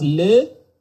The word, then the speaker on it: Lue: I hope my French isn't too rusty, but I think it's pronounced le. – Okay. – So the E is silent.